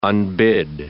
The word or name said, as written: unbid